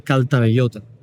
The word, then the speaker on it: Caltabellota: Calvallet. A ver si lo digo bien. Pacto de Caltabellota, en 1302.